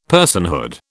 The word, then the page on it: personhood: British English